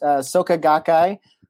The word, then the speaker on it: Soka Gakkai: You pronounced it perfectly, by the way. It's Soka Gakkai International is the lay Buddhist organization that I'm a young men's division within and a member of.